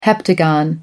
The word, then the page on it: heptagon: American English